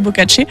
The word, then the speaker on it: Boccace: C'est inspiré de livres, pour le coup, qui sont sortis en 1350, je crois, par Boccace, ou Boccace, je pense que ça se prononce plutôt Boccace,